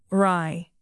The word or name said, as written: wry